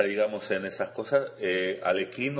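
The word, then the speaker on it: Alekhine: Yo, claro, yo escribo Alequín porque él escribía así, esa, eh, aunque pronunciado debería ser aproximadamente, si mi ruso no es tampoco Aliojin o Aliojin, algo así.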